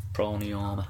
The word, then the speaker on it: pranayama: Pranayama. – When it's the A on the end is silent. – If pranayama. Pranayama. – If you go into classical Sanskrit, the A's on the end are silent.